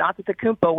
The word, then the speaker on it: Antetokounmpo: and then you can pr- you can pronounce it uh, the way I just did, Antetokounmpo, where there's more T's and T's. Um, that's more of the Greek version,